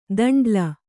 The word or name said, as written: ದಂಡ್ಲ